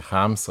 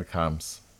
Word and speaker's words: Charms: Äh, man spricht den Daniel Harms aus, aber wie man schreibt, das ist halt immer so eine kleine Geschichte, weil auf, so im englischen und deutschen Sprachraum wieder mit CH geschrieben, C-H-A-R-M-S und äh, sonst eher im, so im östlichen Europa mit KH, mit Kams. Also, aber man sagt Daniel Karms, spricht man aus.